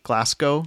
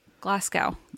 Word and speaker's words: Glasgow: How do you say Glasgow? There's no way that's right. – Glasgow. I'm sure... – Glasgow? – Yep. – Okay. – No. Glasgow. – Glasgow. God.